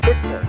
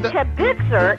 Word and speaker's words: kibitzer: Would you please say the word kibitzer with the emphasis on the kib instead of the kibitzer? – Oh, shalom, bimromav.